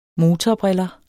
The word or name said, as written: motorbriller